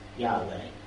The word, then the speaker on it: Yahweh: And so when they wrote it down, they would write YHWH, which sometimes people pronounced Yahweh, because that was the only way they could think of saying it.